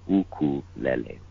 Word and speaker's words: ukulele: Well, the way that I was taught, you know, because it is actually made up of two Hawaiian words, and the first being uku, which means flee, and the second part of the word is lele. So the correct pronunciation is ukulele, ukulele.